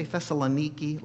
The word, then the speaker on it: Thessaloniki: Did you hear me say Thessaloniki last week? That's the pronunciation of the city.